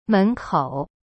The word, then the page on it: 门口: men-kou